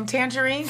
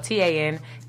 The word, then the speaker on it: Tanjareen: Lady, Tanjareen can be found on all forms of social media. If you know how to spell my name, it's not spelled like the fruit. It's spelled TanjareeN.